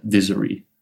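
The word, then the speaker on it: Dizary: Laten we allereerst even hebben over Dizzery. Zeg ik dat goed zo? – Ja, zeg je helemaal goed. – Ja. D-I-Z-A-R, Griekse I.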